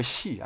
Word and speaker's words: Xi: No, she. I say she. Yeah. Like S-H-E. – Yes, so S-H-I. – Yes, so, she.